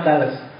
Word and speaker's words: Thales: Kalau kamu enggak percaya bacanya memang Thales.